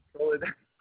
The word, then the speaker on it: Soledad: Solar Dad. Oh. – Soledad. Not solar, Soledad. Sol E with the E. Soledad.